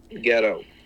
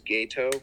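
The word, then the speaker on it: Geto: Ghetto. – Oh, okay. – Technically, the proper pronunciation is Gato.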